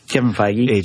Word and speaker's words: Kevin Feige: And at least that's what Kevin Feige said. I hope I said the name right for Kevin Feige.